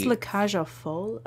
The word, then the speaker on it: La Cage aux Folles: Pretty sure it's La Cage aux Folles. Like, you pronounce the L in that.